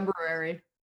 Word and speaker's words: February: No, I say February, even though that's not how it's spelled. – That's how I say it too. Yeah. – Yeah. – Without the first R.